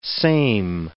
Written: same

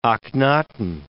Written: Akhenaton

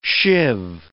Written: shiv

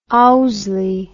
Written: Owsley

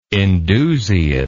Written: indusiate

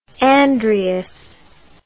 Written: Andreas

